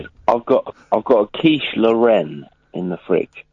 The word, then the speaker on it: Lorraine: You don't pronounce Lorraine Lorraine. That's Lorraine. – You pronounce the region Lorraine. – No. But it's pronounced Lorraine. You're speaking of a quiche Lorraine.